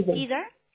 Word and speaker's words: Susan: Caesar.